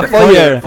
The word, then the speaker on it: Feuer: Feuer, se pronuncia Feuer, que tío.